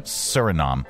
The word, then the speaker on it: Suriname: The South American country of, how do you pronounce that? Suriname. Suriname. Uh, had been on the list, you know, along with New, Papua New Guinea and the U.S.